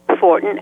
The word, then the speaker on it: Fortin: S-U-E, Fortin, F-O-R-T-I-N, and the last name, as you've pronounced it, McPhee, M-C-P-H-E-E.